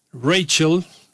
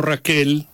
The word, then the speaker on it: Rachel: Bueno, como ya estábamos escuchando a la princesa Stephanie, Rachel, que no Raquel, hay que pronunciarlo según lo puso el Centro de Huracanes de los Estados Unidos,